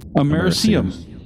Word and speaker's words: americium: High levels of americanium. How do you pronounce that? – Americium.